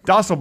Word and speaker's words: Dostal: Dostal, by the way, rhymes with hostel. – Sort of. There's a T in there. Hostel and Dostal.